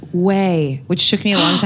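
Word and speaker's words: Ouai: I know, but it's like O-U-O-I. – Oh, yeah. – It doesn't look like that, but it's pronounced Way. – Not we. – No, it's Way.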